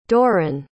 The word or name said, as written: dorren